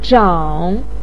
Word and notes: zhang: 3

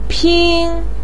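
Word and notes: pin1: tone 1

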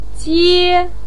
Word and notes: jie: 1

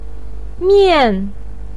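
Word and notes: mian: tone 4

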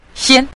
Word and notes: xian: xian5

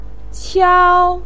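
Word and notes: qiao1: tone 1

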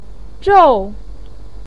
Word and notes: zhou: 4

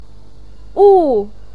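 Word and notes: wu: tone 4

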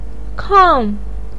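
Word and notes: kang: tone 4

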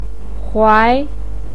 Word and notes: huai2: pinyin

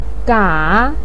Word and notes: ga: 3

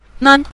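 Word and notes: nan: tone 5

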